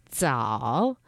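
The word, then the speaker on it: zao (枣): But it's pronounced zao, and it sounds like early.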